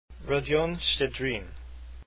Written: shchedrin

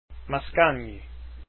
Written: mascagni

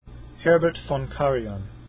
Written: karajan

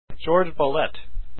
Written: bolet

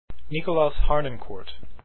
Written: harnoncourt

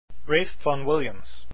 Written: Vaughan Williams

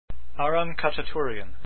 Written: Khachaturian

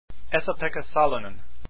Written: salonen

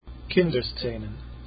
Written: kinderszenen

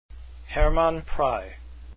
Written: prey

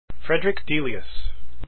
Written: delius

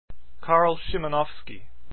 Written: Szymanowski

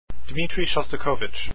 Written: shostakovich

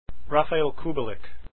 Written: kubelik

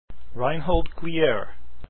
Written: gliere

